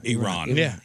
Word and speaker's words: Iran: Like, okay, remember, it's Iran. – Iran.